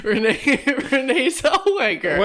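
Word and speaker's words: Renée Zellweger: Maybe Renelle Zellweger. Renelle Zellweger. How do you pronounce her name? – Renee Zellweger.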